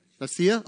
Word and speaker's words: Mysia: after they were come into Mysia, how do you say that? Mysia? Okay, that's what I thought.